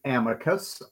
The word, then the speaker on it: Amicus: Since you've rebranded, is it Amicus or Amicus? – We've been pronouncing it Amicus, although we'll take any comers. It doesn't matter. So whatever you like to call it, as long as you're listening, happy to have you be a part of the show.